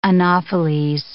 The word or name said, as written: anopheles